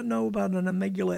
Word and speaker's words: amygdala: So this thing, let's get back to this amygdala. I said the wrong syllable. I had it on. – Amygdala.